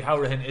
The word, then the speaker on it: Hourihane: But I think it's safe to say Hourihane is the pronunciation that we want down in West Cork.